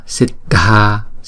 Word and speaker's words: Siddha: Chữ này chúng ta đọc là Siddha, Siddha, yoge. Chữ đa, chữ vô, chữ ghê. Ba chữ đó chúng ta đọc hai nhịp. Hết. Không. Siddha. Chữ đờ và chữ hát ghép lại chúng ta đọc là đờ, đờ.